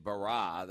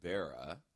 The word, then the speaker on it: Barah: "created Barah." That's why, where we get Barah Ministries from. We had to throw an H on there because people would pronounce it Bera.